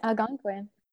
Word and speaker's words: Algonquin: Algonquin.